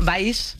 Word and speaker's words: Valls: Arturo Valls, que ha venido... – Valls. – Es que es difícil, ¿eh? Vaya, que cuesta cuando no sabes pronunciar algo, ¿eh? – Sí, pero se trata de...